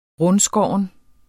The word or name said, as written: rundskåren